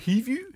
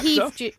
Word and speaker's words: Hivju: Kristofer Hivju. – Kristofer Hivju.